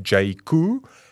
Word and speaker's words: Jaecoo: Of course, Jaco, spelled J-A-E-C-O-O, pronounced Jaco.